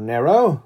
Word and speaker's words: Nero: I just say Nero, kind of using the standard English pronunciation. Nero is more or less correct. I don't worry too much about that.